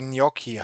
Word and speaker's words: Gnocchi: Also ich wurde mal belehrt von dem Italiener, der mir gesagt hat, dass das ja Gnocchi heißt.